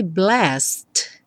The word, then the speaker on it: blessed: Esse blessed fica tão reduzido porque o E não é pronunciado, então seria blessed. Que praticamente não se fala mesmo o D, então você já vai do S direto para o me.